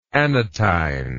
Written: anatine

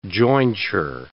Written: jointure

